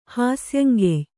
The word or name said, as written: ಹಾಸ್ಯಂಗೆಯ್